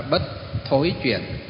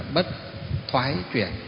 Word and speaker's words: Bất Thoái Chuyển: Nhưng cách phát âm thì miền Nam đọc là Bất Thối Chuyển, miền Bắc chúng ta đọc là Bất Thoái Chuyển.